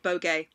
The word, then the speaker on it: Vogue: but in Spanish, you pronounce it Bogue.